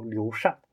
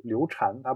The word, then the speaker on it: 刘禅: 我也说，就因为因为因为有些人要读古音嘛，有些人要读现在的音，我怕我我读光读现在的音被一些人说我没文化，所以我我就读一下古音啊。但其实其实现在这两个读音都被接受。嗯，大家看袁腾飞讲历史的时候，经常说刘刘禅，他不说刘禅，难道他真的不知道那个字应该读风扇的扇？